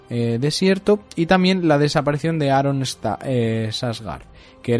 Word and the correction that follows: Santagar: era el, creo que lo he dicho bien, es que tiene un nombre un poco, Aaron Santagar, perdón, era el, herrero eh, de, la, de la Fortaleza Roja.